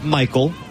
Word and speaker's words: Maikol: Michael Hernandez, who spells his name M-A-I-K-O-L, but pronounced just like Michael.